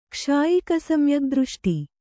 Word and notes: ಕ್ಷಾಯಿಕಸಮ್ಯಗ್ದøಷ್ಟಿ: Kannada

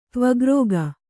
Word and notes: ತ್ವಗ್ರೋಗ: kannada

